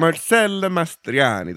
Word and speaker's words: Marcello Mastroianni: Marcello Mastroianni got nominated for Divorce Italian Style. – Marcello Mastroianni. That's how you're supposed to say it.